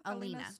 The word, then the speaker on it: Alina: It follows, the main character is it Alina or Alina? – Alina. Alina Starkov.